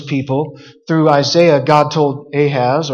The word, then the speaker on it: Ahaz: This was spoken originally to King Ahaz, actually Ahaz is how they would have pronounced it,